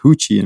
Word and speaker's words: Ruth: And my next door neighbor, this lovely lady called Huchi Ruth, which is pronounced Huchi in Portuguese, she said, oh, it would be a nice product.